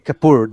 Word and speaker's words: Kapoor: No sé si lo dije bien, pero Kapoor. él es Anil Kapoor, de poor.